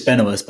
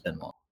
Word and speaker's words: Benoist: It's Benoist, but like she's French. – Oh, Benoist. – Yeah. – Benoit. – I mean, she's not French, but like the last name is French. Sorry, so it'd be pronounced Benoit.